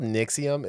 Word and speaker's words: NXIVM: It's called Nixium. It's spelled N-X-I-V-M. Not sure where Nixium comes from out of that.